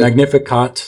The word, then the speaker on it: Magnificat: Magnificat. Is that the way to pronounce it? – Magnificat. – Magnificat.